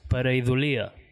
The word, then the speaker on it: pareidòlia: En castellà és pareidòlia, ah, crec que... Però ho vam demanar a un erudit lingüista amic nostre que mos va dir que ho havien de pronunciar pareidòlia, ¿vale?